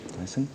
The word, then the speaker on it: Thyssen: Ou Thyssen, I don't know exactly how to pronounce it. – Thyssen? – Ok.